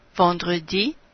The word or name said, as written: vendredi